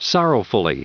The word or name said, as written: sorrowfully